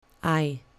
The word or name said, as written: i